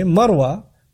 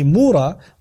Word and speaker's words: Marwa: By the way, Marwa, which the Jews mispronounced deliberately, Mura, was the place where Ibrahim a.s.